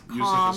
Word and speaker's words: Yousif: Yousif is spelled.